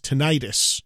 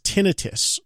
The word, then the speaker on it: tinnitus: Now, I've heard of this, but for a long time, I always thought that it was pronounced tinnitus. It's not. It's pronounced tinnitus, okay?